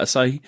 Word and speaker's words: Asahi: I think it's pronounced Asahi, but I'm not certain. Yeah. It's Asahi. – Okay.